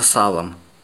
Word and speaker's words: Сало: Теперь мы называем его просто Салом. Официально Сало, с ударением на последний слог.